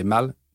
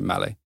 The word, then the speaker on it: Malle: So we took a French word, but bastardized a pronunciation from I want to say mal to male. It's with an English twist.